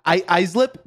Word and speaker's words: Islip: As always, I got my little friend over here, my coworker, straight out of Long Island, Islip, New York. I say it right? – Islip? No. Well, yeah, you said it right.